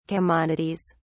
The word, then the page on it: commodities: British English